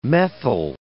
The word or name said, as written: methyl